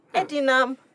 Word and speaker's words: Edinam: Edinam, 3 syllables, you really can't get it screwed up, right?